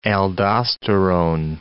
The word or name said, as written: aldosterone